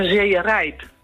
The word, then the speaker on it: Zeerijp: Met de klemtoon op Rijp. Want als je het in Groningen zegt, dan zeg je Zeerijp. – Zeerijp. – En dan is de klemtoon ook op. Op het riep in plaats van het rijp.